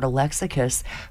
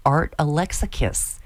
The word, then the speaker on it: Alexakis: You know, he wrote that, Art Alexakis, whose name you end with a kiss. That's how I've always remembered how to say it, Art Alexakis.